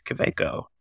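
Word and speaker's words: Kaweco: How do you say it? Is it Kaveco or is it Kaveco? – It's Kaveco. That's German. She corrected me.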